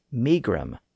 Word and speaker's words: megrim: They altered the final syllable to grim. So in the 1500s, the word was usually rendered as megrim.